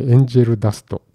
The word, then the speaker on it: 天使の涙: 天使の涙」と書いて「エンジェル・ダスト」と読み